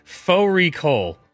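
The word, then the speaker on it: Fårikål: Faux recall, which is a mutton and cabbage. It's spelt like Fari call.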